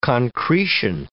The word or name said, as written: concretion